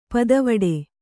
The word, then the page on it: ಪುದಿವೆಟು: pudiveṭu